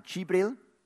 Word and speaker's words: Jibril: Sie nennen ihn Jibril, Gabriel. Aber in diesen Sprachen schreibt man ja Vokal nicht, nur Konsonanten. Darum ist eigentlich der Name Jibril.